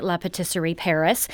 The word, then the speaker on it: La Pâtisserie Paris: And for you French purists, that is how they're pronouncing it, La Pâtisserie Paris.